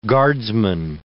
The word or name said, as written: guardsman